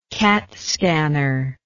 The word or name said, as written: CAT scanner